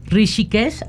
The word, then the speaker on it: Rishikesh: Hoy nos acompaña Javier Noyola Valdés, maestro en educación sexual, coach ontológico, practicante y maestro de yoga, formado en Rishikesh, ¿así se pronuncia? – Así se pronuncia.